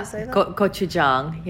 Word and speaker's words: gochujang: And it's kind of funny also the fact that, you know, sources like gochujang, I'm going to say this wrong, is it gochujang? – Yeah, gochujang, yeah.